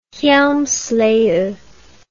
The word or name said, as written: hjelmslev